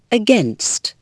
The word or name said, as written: against